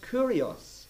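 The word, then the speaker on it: kurios (κύριος): It's a word pronounced kurios, but not here.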